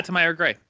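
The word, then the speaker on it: Tamyra Gray: Tamira Gray? – Yeah, Tamira Gray.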